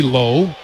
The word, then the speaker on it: Lowe: spells his name L-O-W-E, pronounces it Low.